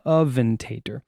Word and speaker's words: Aventador: That's not the way it's spelled. Let's see. Aventador. Okay, there we go. Thank you. He's helping me out. Aventador.